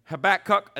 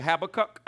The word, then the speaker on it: Habakkuk: Habakkuk, and some people say Habakkuk. I don't care how you say it.